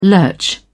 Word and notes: lurch: British English

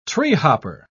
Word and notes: treehopper: British English

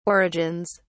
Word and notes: origins: British English

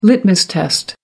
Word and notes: litmus test: American English

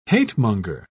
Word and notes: hatemonger: British English

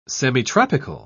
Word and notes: semitropical: British English

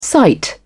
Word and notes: cite: British English

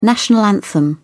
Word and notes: national anthem: British English

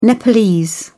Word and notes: nepalese: British English

